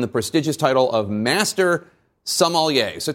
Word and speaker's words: sommelier: First of all, I'll say I'm very impressed with your pronunciation of sommelier. It's not an easy word, so I'm guessing you've spent a little time in wine lists and restaurants.